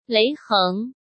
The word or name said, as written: Lei Heng